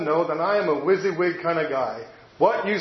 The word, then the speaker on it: WYSIWYG: It's pronounced WYSIWYG, in case you've never heard that word, WYSIWYG.